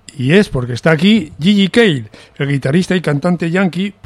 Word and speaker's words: JJ Cale: Bueno, pues este Gigi Cale, que se escribe JJ Cale,